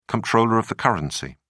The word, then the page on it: comptroller of the currency: British English